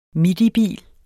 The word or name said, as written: midibil